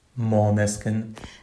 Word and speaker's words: Måneskin: Måneskin. – Może jeszcze raz. – Måneskin.